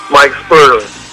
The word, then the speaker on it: Mike Sperling: Mike Sperling.